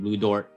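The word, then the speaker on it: Lu Dort: And Luke Gantz, or however you say his name, Lou Dort. – There you go.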